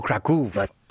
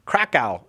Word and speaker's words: Kraków: You just put the accent, though, a little bit more on the Kraków. – Oh, Kraków. Oh, yeah. Oh, okay. Yeah. Now, Krakow is what we would say here in the United States. And we're working on getting the entire world to pronounce things exactly like we do, but we have not accomplished that feat yet. But here in the U.S., we say Krakow.